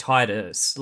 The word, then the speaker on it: Tidus: Yeah, Tidus sounds so much better. Because Tidus sounds like it could be a Romany sounding name, and that's definitely what they're going with how it's pronounced Tidus.